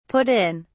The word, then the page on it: put in: British English